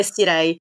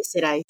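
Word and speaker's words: Cirey: Comunque è Sirei, lo Chateau di Sirei. Lo Chateau de Sirei.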